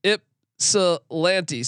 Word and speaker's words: Ypsilanti: Swiss Horton goes, it's pronounced Ypsilanti.